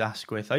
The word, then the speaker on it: Asquith: Oh, no, it really is that plain and dull. No, it is Asquith. I used to get picked on at school, actually, for having a quiff on my ass with the word Asquith. So, yeah, that's how I always tell people to pronounce it.